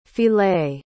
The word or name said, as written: filé